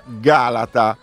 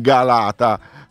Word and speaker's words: Galata: Un ascoltatore ci scrive: a proposito di parole, il museo del mare genovese si chiama Galata, con l'accento sulla prima A, e non Galata come nella pubblicità.